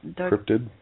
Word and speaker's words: cryptid: Let's start out with all the for all the cryptoid fans. Is that how you say it? – Cryptid? Cryptozoology, yeah. Cryptid yeah.